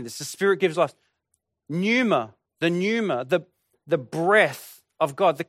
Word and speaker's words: pneuma: And in the New Testament, in the Greek, the word Holy Spirit or Spirit is pneuma. Now, for those of you who haven't seen that word before, the P is silent, pneuma.